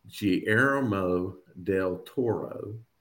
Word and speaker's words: Guillermo del Toro: C, and I looked this up, Guillermo del Toro.